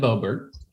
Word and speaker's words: Boebert: Is Representative How do you say her name? Is it Boebert? What is her name from Colorado? – Yes, Congressman Boebert.